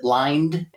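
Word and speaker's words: Lynde: Did I say it right? Is it Lyons and Harry Bradley Foundation? – That's correct. Good job.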